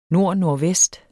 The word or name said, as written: nordnordvest